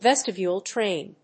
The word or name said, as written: vestibule train